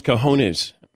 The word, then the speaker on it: cojones: cajones. – All right. Thank you, Butch. Yeah. The cajones. Get a text yesterday. Ross Tucker doesn't know how to pronounce cojones.